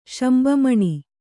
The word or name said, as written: ಶಂಬಮಣಿ